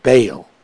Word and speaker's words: Baal: That's B-A-A-L, and it's pronounced like, bailout water, bail, to the sun, to the moon, and to the planets, and to all the host of heaven.